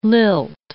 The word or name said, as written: lilt